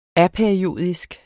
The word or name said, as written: APERIODISK